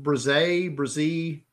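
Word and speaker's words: Bresee: The Clemson guy, Breesy, is it name? Is his name? Or I've heard it pronounced Breezy.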